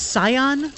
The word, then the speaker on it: Scion: for speeding in his Toyota Is it pronounced Scion? – Yes.